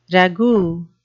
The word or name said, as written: ragout